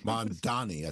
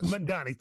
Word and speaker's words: Mamdani: Is it Mamdani? – Mamdani, I think it is. – Mamdani, thank you.